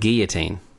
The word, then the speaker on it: guillotine: Or the guillotine, as we say here in lame-o land. – The guillotine, as we say... Correctly. – No. – You say guillotine. – It's pronounced guillotine.